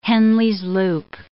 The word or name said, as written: Henle's loop